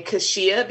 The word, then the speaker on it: Keshia: But a lot of people pronounce my name Keshia because of the way it's spelled.